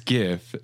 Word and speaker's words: GIF: It's GIF. The first word is graphics, interchange format, graphics, hard G, GIF.